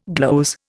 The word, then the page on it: gloze: Indian